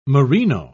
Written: merino

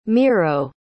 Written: miro

